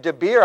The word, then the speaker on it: Debir: Oh, and yes, some of those guys fled away over to another city close by named Debir. I think I'm saying it right, D-E-B-I-R.